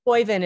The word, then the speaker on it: Boivin: Boivin is what I say.